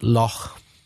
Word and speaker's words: Lough: L-O-U-G-H. Can you say that word? – I've never heard that. Is it Lough? – It's pronounced Loch. – A Loch, like the Scottish Loch. – Ah, well, Loch or Loch. – Loch is L-O-C-K in Scotland, isn't it? – Is L-O-C-H.